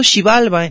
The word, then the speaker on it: Xibalba: En algunas fuentes lo acentúan Xibalba, Xibalba. Debatido cuál de las dos pronunciaciones. Por el día de hoy es posible que esté pronunciándolo de ambas maneras.